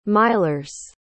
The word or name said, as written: milers